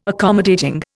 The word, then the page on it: accommodating: Indian